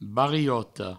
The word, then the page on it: bariota: barioto; Provençal